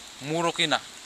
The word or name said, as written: murukina